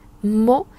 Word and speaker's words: maux: chẳng hạn như là bây giờ chúng ta đọc là mộ và nó viết là à, m o t và chúng ta có một từ nữa là mô m a u x chúng ta cũng đọc là mô đọc như nhau